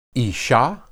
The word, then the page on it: isha: hebrew